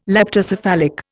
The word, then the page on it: leptocephalic: Indian